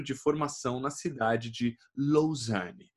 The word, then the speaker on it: Lausanne: Falei certo a pronúncia, Netão? Né, Me conta a sua viagem, e aí? – Fala, beleza? Beleza, e você, cara? – Obrigado pelo convite, primeiramente. E se falou certinho, sim, é Lausanne.